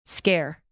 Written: scare

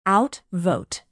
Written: outvote

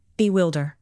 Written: bewilder

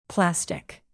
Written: plastic

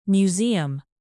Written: museum

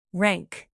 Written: rank

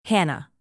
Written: hannah

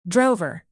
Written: drover

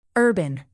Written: urban

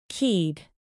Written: keyed

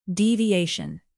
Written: deviation

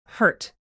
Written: hurt

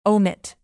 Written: omit

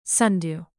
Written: sundew

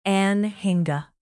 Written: anhinga

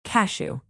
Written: cashew

